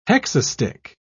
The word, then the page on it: hexastich: British English